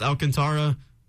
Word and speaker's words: Alcantara: I think it's Alcantara. It might have more of like a Spanish pronunciation, but let's just roll with Alcantara.